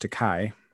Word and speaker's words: Tokaji: Tokaji. I can't even spell that. But apparently pronounced Tokaji is what my special prize was.